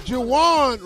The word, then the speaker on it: Juwan: Juwan rhymes with Deshaun.